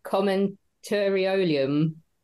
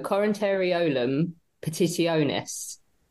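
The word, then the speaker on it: Commentariolum Petitionis: Is it commentariolium? No, Corinteriolum petitionis. – That sounds better to me. You put the stress on the wrong syllable.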